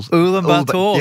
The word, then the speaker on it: Ulaanbaatar: Ulaanbaatar.